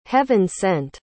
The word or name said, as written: heaven-sent